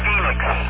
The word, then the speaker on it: Phoenix: Is that F-E-L-I-X Contact? Is that the brand name that you're discussing? – No, it's Phoenix, like Phoenix, Arizona. Phoenix Contact. – Okay, Phoenix, P-H-O-E-N-I-X, like the city in Arizona.